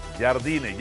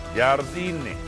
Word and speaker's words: Jardine: Ya presentaron oficialmente a Jardine. Ya hasta él enseñó cómo pronunciarlo. Jardine no jardiné, no jardine, no el jardinero.